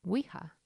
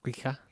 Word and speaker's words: Ouija: It's O-U-I-J-A. I grew up thinking it was Ouija with an I.